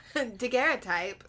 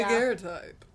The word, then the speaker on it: daguerreotype: Okay, so we did confirm the pronunciation of... – Daguerreotype. – Yeah, there we go. – Daguerreotype.